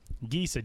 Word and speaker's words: Gesa: Gesa, G-E-S-A Stadium.